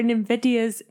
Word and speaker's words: NVIDIA: N-V-I-D-I-A.